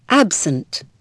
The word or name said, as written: absent